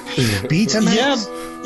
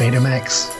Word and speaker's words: Betamax: Betamax? – Yeah, but it's only pronounced with the long E sound instead of the long A sound in the United Kingdom, so... – I would call it Betamax.